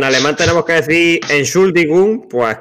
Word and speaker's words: Entschuldigung: Entschuldigung.